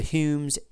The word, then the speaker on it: Home: Anyway, so when you see the Humes and the, which by the way is spelled home, but I believe it's pronounced Hume in Scotland, and the Maxwells, you see those two there.